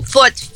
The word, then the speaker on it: foot: Foot é um som do U curto, foot. E tooth já é longo, que é igual good e book. E depois vai ficar food, que é longo.